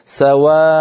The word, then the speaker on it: سواء: الله يسلمك، كلتاهما تنتهيان بتنوين منصوب، كلتاهما تنتهيان بتنوين منصوب، فالتنوين المنصوب يوقف عليه بإبداله ألفا، فسوء مثلا كلمة سواء سواء، التنوين هذا نحوله إلى ألف بعد الهمزة، فنكون النطق هكذا سواء،